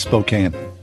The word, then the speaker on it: Spokane: My apologies. It is Spokane. Okay. – Yeah. – I'm just a yinzer. Okay. I'm saying Spokane. Okay. Sorry. My apologies. We've heard from several people about that. My mispronunciation. Spokane.